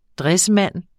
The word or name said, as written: dressmand